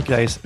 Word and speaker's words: Claeys: Je m'appelle Jérémy Claes, je suis illustrateur à Paris et vous pouvez me suivre sur Instagram @JérémyClaes.